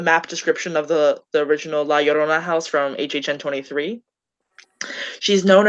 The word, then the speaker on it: La Llorona: Okay, I also did La Llorona, and I've been looking at it all day, and I knew for years that the two L's in Spanish words make a Y sound, but no one pronounces it that way.